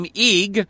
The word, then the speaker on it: Ygg: Interestingly enough, according to Wikipedia, one of Odin's names is Yig, Y-G-G. The same is Norse for the word ash. This name, Yig, closely resembles the Vedic name Agni, A-G-N-I, in pronunciation.